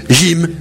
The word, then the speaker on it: Gims: Gims, cela fait comme gym, hein, comme gymnastique. Oui. Donc on prononce gym.